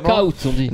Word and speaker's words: Knockout: Ils avaient une série qui s'appelait Knockout Kings, que je prononce très mal, évidemment. – Knockout, on dit. – Knockout, ok, ouais.